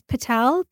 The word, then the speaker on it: Patel: I'm under Alpa Patel. – Can you spell it for Alpa? Last name is Patel, P-A-T-E-L.